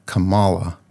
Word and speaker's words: Kamala: I'm saying Kamala, but I know that's wrong. It's Kamala, like Pamela, but Kamala.